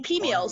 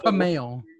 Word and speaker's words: peameal: It's pronounced pameal. – You're using pea meal. – Pea meal. – Pea meal. – P-E-A-M-E-A-L.